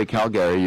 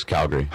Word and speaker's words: Calgary: And we were talking that you're from, and you don't say Calgary, you say Calgary like us. – Yes, Calgary. – How many people say Calgary up there? – I mean, it's split even through Canada.